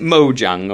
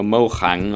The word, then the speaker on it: Mojang: Is it Mojang or Mohang or how do you pronounce it? Anybody know? – I don't know. Is it Mojang? – I guess Mojang.